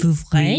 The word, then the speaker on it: Vouvray: Gillette, G-I-L-E-T, Gillette. – Probably Gillette. I'm assuming, yeah. – Okay, Gillette, Jean-Marc Gillette. Vouvray. – Sorry? – Vouvray. – Vouvray. – Vouvray.